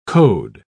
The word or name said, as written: code